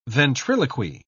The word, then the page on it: ventriloquy: British English